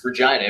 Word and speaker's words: Regina: says Regina rhymes with fun, and you know, because and that's how you say it too. It's Regina. Every time I tell people in Chicago here, you know, isn't it Regina? I'm like, no, it's Regina.